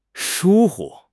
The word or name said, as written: 疏忽